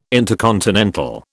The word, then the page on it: intercontinental: British English